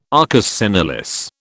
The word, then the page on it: arcus senilis: British English